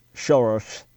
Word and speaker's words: Soros: George Soros, pronounced Soros here in the States, but Soros in Hungary.